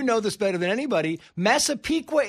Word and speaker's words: Massapequa: We mentioned Massapequa, and I had to ask three times, how do you pronounce this, Massapequa? It's Massapequa. The tribes have come out and said, listen, this is a sign of respect.